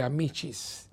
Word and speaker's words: Amicis: Claro, ese C en italiano se pronuncia como CH, ¿no? El mundo de Amicis. Es un libro precioso.